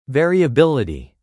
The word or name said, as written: variability